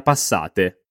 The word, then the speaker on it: passate: Le 18:30 passate. Le 18:30 passate. Attenzione alla pronuncia di questa parola. Passate, passate.